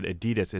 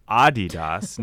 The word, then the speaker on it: Adidas: You said Adidas. It's Adidas, named right. – after Adi Dassler.